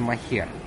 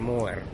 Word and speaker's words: Moher: Там есть место, которое называется Cliffs of, по-русски произносится Moher. – Так. – А не по-английски Moher.